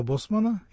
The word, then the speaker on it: Босман: Вспомним дело Босмана или Босмана, если во французской, так сказать, транскрипции с ударением на последнем слоге. Ну, по-моему, он фламандец, поэтому Босман.